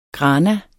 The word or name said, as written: grana